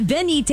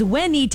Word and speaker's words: venite: No, like, I'll go off on a tangent in the middle of Mass how it's not venite, it's venite. It's pronounced like a W.